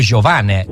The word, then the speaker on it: Giovane: proprio Giovane, così come ci ha detto lui che si pronuncia.